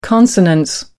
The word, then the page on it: consonance: American English